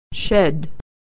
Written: shed

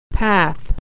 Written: path

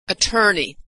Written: attorney

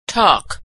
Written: talk

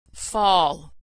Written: fall

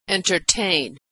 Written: entertain

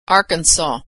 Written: Arkansas

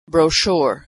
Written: brochure

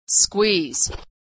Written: squeeze

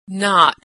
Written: knot

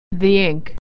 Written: ink

